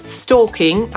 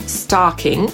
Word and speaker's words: stalking: Stalking albo stalking wymawia się podobnie jak pończocha albo świąteczna skarpeta. Po brytyjsku stalking, po amerykańsku stalking. A więc po amerykańsku i pończocha i stalking są homofonami, czyli wymawia się je dokładnie tak samo.